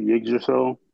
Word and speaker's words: Yggdrasil: It's like Yggdrasil, the big fucking Norse tree.